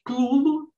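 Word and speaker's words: Cthulhu: Então, ele vai dizer, inclusive, que a pronúncia certa é clulo, é, mas você tem que falar esse nome com a língua colada no céu da boca.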